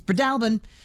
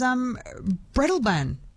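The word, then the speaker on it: Breadalbane: It's Breadalbin. Louise in Nara says, Jess, I've only ever heard it as Breadalban. Oh, I'm probably mispronouncing the mispronunciation.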